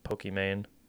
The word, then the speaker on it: Pokimane: Well, her name is Iman, but then she loves Pokemon, and so she made it like Pokimane. Is how you pronounce it?